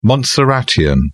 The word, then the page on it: montserratian: British English